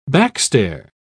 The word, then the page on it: backstair: British English